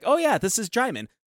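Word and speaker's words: Djimon: It's D-J-I-M-O-N is how you say his first name. – Hmm. – And whenever he talked to Russell Crowe, he asked him, like, how do you pronounce his name? It's like, Jaimon, like Simon.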